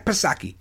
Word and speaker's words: Psaki: But listen to Jen Psaki, Silent P.